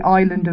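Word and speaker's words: Islay: No, she's named for the island of Isla, with a silent Y, up in Scotland, mm-hmm.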